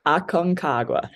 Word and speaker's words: Aconcagua: Aconcagua. say it one more time. – Aconcagua.